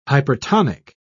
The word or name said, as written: hypertonic